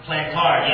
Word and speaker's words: Plantard: Plantard, yeah.